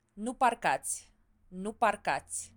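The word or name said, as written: nu parcați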